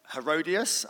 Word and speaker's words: Herodias: This was a territory that was controlled by Herod Antipas and his wife Herodias.